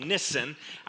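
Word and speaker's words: Nisan: It's called, their New Year month is called Nissan. I remember that because it rhymes with kissin'.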